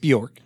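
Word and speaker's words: Björk: Is that how you, am I saying it right? – Yeah, Bjork. Just Bjork.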